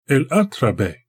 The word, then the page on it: el-a′tra-beh: Galindri